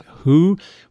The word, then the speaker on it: Hooe: Hoo, which is spelled H-O-O-E, which I swear sounds like hooey,